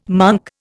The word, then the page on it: monk: Indian